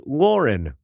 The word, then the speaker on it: Lauren: Just to help alleviate your grossed-outedness at saying Ralph Lauren, you were right the first time. His name is actually pronounced Lauren, like the woman's name.